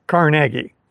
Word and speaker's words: Carnegie: Andrew Carnegie and his family pronounce their last name Carnegie.